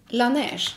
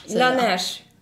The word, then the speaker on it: Laneige: Laneige. – Laneige.